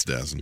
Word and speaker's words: DAZN: Also, somebody wanted to point out that Dazen's actually pronounced DAZN. – It's Dazen.